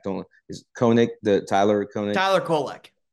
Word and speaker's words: Tyler Kolek: Tyler Kolek.